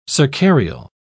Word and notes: cercarial: British English

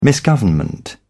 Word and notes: misgovernment: British English

